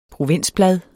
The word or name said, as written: PROVINSBLADET